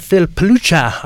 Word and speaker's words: Pelucha: So now in studio, we have Phil Pelucha.